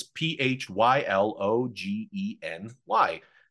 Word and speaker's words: phylogeny: and if you pronounce it, it's P H Y L O G E N Y. And it could be phylogeny.